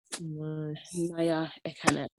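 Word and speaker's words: Nia Ekanem: But the N-I-A is spelled, it's pronounced as Naya. Naya Ekanem.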